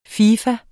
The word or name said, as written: fifa